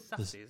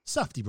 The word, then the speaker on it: Safdie: I can't pronounce their surname. – It's Safdie, isn't it? – Safdie Brothers. Directed by the Safdie Brothers.